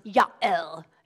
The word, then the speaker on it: Yael: In the Hebrew, she's actually, her name is pronounced Yael.